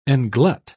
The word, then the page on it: englut: British English